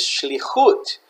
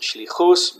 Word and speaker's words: shlichut: An agency or ministry in modern Hebrew is shlichut. Some people pronounce it shlichus, but that's just the Ashkenazic pronunciation of the tav into an S sound.